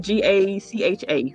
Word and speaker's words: gacha: it it's, it's spelled G A C H A.